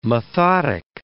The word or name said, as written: methodic